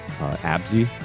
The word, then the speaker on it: Abxy: I use the name Abzy, which is spelled like A-B-X-Y.